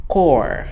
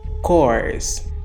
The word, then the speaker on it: Corps: Corps, C-O-R-P-S o P e o S são mudos se estivermos falando no singular, core. Mas se estivermos falando do plural, apenas o P é mudo. Então fica cores.